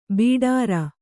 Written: ಭಟಾರ

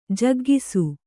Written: ಜಗ್ಗಿಸು

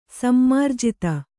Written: ಸಮ್ಮಾರ್ಜಿತ

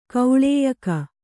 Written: ಕೌಳೇಯಕ

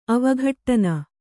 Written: ಅವಘಟ್ಟನ

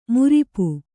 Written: ಮಾರಾಪು

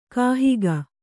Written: ಕಾಹಿಗ

